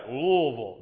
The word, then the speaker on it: Louisville: I said it right. Louisville, not Louisville. I was corrected quickly on that.